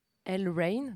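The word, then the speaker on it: L'Rain: Euh, elle s'appelle alors, je vais réussir à bien prononcer ce nom, elle Rain, donc elle apostrophe Rain comme la pluie.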